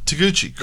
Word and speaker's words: Taguchi: Taguchi.